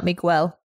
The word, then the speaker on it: Miguel: It's M-I-G-U-E-L, right? – So you don't pronounce the E, basically? – No. Is that how it's spelled? M-I-G-U-E-L? – Yes. – Yeah, it's Miguel. – Okay. What happens to the E? Why don't I do or the U? Why don't you do a Miguel? – It's Spanish. You don't pronounce the G, the gue. – Oh. – It's not a gue.